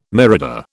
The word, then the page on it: mirador: British English